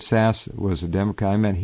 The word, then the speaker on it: Sasse: But look up S-A-S-S-E, Sass, pronounced Sass.